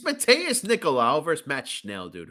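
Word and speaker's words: Matheus: This first name, Matthews Nicoloa. – What are you looking at? Oh, that's Mateus, bro. You don't know how to pronounce Mateus? – No, I'm fucking idiot from Boston.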